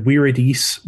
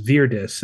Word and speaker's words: viridis: So, like the word viridis, which eventually became verde, um, verdant, green in modern English, was even at the time of classical Latin being pronounced as virdis instead of viridis.